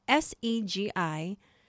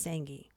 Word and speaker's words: Sengi: It's spelled S E G I. Is it Segi or Seji. He was like, Girl, none of the above. It's Sangi.